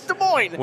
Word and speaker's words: Des Moines: I get so irritated when people say Des Moines or Des Moines because they at least acknowledge on one of the halves that the S is silent. Yes. And I'm like, it's silent on both.